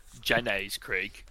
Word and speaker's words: Jenny's Creek: You need to say it right. It's Jenny's Creek.